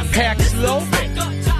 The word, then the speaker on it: Paxlovid: It's pronounced Paxlovid.